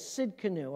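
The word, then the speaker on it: Tsidkenu (צדקנו): And so the T is silent. So you take the T away and say it just like it is, Tsidkenu, all right?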